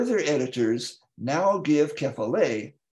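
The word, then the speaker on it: kephale (κεφαλή): It's the correct pronunciation is kephale. – Oh, sorry. Yes. Kephale.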